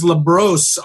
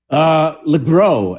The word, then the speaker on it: LeGros: Mr. Jesse Borrego, James, I actually have your name as LeBros on my email, but it's LeGros? – LeGros, actually. – Okay, LeGros. It's French, you know, the S is silent, but yeah.